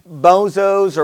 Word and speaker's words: Bezos: You, you know, you, you know, you think about the, uh, who is it, Jeff Bezos or whatever, whatever his name is, the Amazon guy.